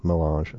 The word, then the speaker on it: Mélange: of a lovely little cookbook called Mélange.